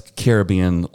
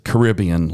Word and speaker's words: Caribbean: And that word puts the stress on the first syllable. So probably like Pirates of the Caribbean is the most original way to say it.